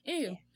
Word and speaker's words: Saay: And I think that's how you say her name. Mm-hmm. Say? Mm-hmm. S-A-A-Y. Say.